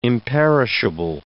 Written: imperishable

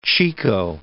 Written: chico

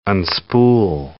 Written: unspool

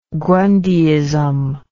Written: grundyism

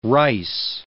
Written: rice

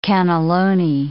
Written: cannelloni

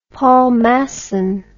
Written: Paul Masson

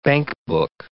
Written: bankbook

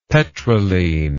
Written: petrolene

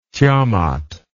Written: tiamat

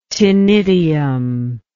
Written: ctenidium

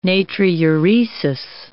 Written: natriuresis

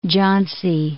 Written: Jhansi

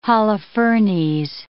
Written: Holofernes

